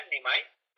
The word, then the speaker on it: NEEMEYE: se escribe así como se escucha, Nimai, pero este juego es como, como en inglés, ¿no? La pronunciación en inglés, que la doble E se convierte en I y, I de ojo, de Que igual pues ahí también te pueden empezar a seguir, ¿no?